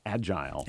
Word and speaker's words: agile: My word for the day is agile, as some people pronounce it, agile.